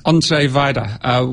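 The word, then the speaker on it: Andrzej Wajda: Yeah, Andre Wajda.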